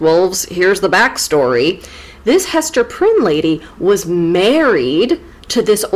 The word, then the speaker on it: Hester Prynne: What did you say her name was? Esther Prine? Oh, Hester Prynne, okay.